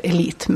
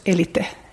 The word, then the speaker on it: Elite: Mycket, och det är också så att det finns lite så det finns en legendarisk konstnärsrestaurang som svenskarna tror heter Elite, men den heter förstås Elite eftersom vi uttalar allting bokstavligen i Finland, som låg så här några i mina kvarter liksom.